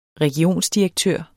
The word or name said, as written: regionsdirektør